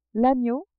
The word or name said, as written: agneau